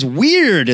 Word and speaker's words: Wyrd: My pick for next week is Weird, and that's spelled W-Y-R-D.